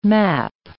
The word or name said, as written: map